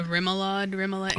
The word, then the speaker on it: remoulade: Also, a remoulade. I don't never know how to say it. – Remoulade.